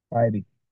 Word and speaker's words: Bybee: Bybee.